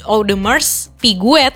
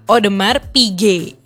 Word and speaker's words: Audemars Piguet: Jadi kalau misal pakai pronunciation yang aslinya emang agak-agak keseleo nih. Nah, habis aku cari tahu cara baca yang benernya itu ternyata Audemars Piguet gitu. S-nya hilang, terus habis itu T-nya juga hilang gitu.